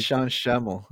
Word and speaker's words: Sean Schemmel: Sean Schmeckle or Schmeckle, whatever they pronounce it. – Sean Schemmel.